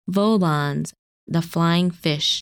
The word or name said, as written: Volans